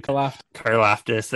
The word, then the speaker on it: Karlaftis: It'll get you kind of ready for them, where they have George, I'm going to say his last name wrong, I always do. – Karloftis, there we go.